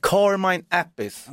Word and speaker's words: Carmine Appice: Carmen Appice? – Exakt, Carmine Appice. Ja, eller Appice, det är, man får välja uttal själv.